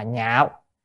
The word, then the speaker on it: nhạo: Tại vì ở đây có chữ thọ hành, văn pháp nhạo thọ hành, chữ này có 3 âm. Ở đây đọc là nhạo.